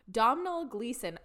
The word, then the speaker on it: Domhnall Gleeson: On June 18th of 2014, the Diagon Alley red carpet premiere took place with the Domhnall Gleeson.